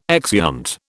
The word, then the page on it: exeunt: British English